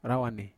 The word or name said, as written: rawane